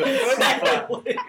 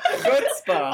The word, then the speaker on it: chutzpah: Chutzpah. Pronounce it like a Jew. Chutzpah. Chutzpah.